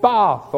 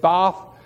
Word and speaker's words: Bath: There's also a place called Bath or Bath, whichever way you want to pronounce that. We call it Bath.